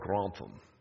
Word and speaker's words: Grantham: So upper left, there's the Earl of the place, Lord Grantham, and you got to say it like Lord Grantham. Sort of that way.